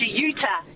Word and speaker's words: Utah: We've got, is it Jitar? Is that how I pronounce it? – I think you're talking to Utah. – Utah. Sorry, Utah. – Yeah, like Utah in America.